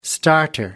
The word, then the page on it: starter: American English